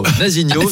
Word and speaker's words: Nazinho: Nazinho, Nazinho. – C'est vrai que ça se prononce avec l'accent chantant.